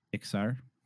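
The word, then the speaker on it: Iksar: Everyone made an Ixkar. Maybe we can talk about that. How do you pronounce the lizard race? – Ixar.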